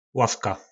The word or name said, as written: lavka